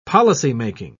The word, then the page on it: policymaking: British English